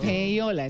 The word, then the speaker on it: payola: Ah, ah payola. Entonces se pronuncia, yo diciendo payolei y es Payola, hola, ah, total.